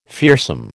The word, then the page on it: fearsome: American English